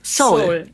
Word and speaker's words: Seoul: Warum es gibt im Koreanischen zwei O's. Das eine ist O und das andere eher so ein O. Und das Soul O ist das O. – Oh, O, Soul. – Ja, Soul. – Soul. – Und ja, deswegen, es wird auf der englischen Schriftweise macht man dieses O. Schreibt man das mit E-O.